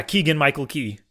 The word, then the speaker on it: Keegan-Michael Key: Yeah, Keegan-Michael Key.